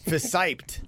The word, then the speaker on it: fissiped: How do you say that? – Fissiped. – It looks like fissiped. – Yeah. Fissiped.